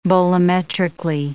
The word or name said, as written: bolometrically